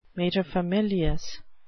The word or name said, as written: materfamilias